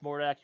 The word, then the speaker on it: MORD4K: You can find me on Twitter as MORD4K, pronounced Mordak.